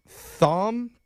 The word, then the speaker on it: Thom: His name, and I apologize if I pronounce this wrong, is it Thom? T-H-O-M? – Yeah, it's Thom. There's an H. – Cool. – Oh, and it's pronounced okay. – Like Thom with an H.